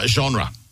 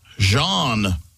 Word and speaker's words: genre: Genre. – Genre. – Like music genre. He pronounced the word as Jean. – Huh? – He didn't say the R.